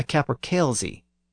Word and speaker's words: capercailzie: For example, a type of chicken or cock that's native to the Scottish Highlands is either called a capercaillie or a capercailzie. The first pronunciation is the older pronunciation with the Y sound, and the second pronunciation is the newer pronunciation with the Z sound.